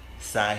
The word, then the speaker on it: Sy: You already know it's your boy Izzy Sy here. Sigh here, not see here. I don't know why people be saying that shit. It's pronounced how it's spelled.